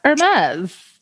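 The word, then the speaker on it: Hermès: Yeah, Hermes.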